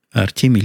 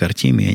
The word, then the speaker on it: Артемий: Артемий Лебедев произносится как Артемий, а не Артемий.